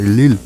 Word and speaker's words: Lil Louis: Alors, c'est le nom de la chanson, la musique dure plus de 9 minutes, quasiment 10, c'est Lil Louis. Alors, moi je vais l'appeler, je vais le prononcer à la française, c'est Lil, oui.